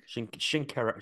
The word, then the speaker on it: Shinkaruk: Hunter Shinkaruk. Have I said that right, do we think? – Shinkaruk, I think. Shinkaruk. I think you pretty much got it, yeah.